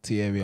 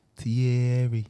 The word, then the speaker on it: Thierry: Thierry Henry. – Say it properly, man. – Thierry.